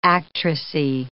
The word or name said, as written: actressy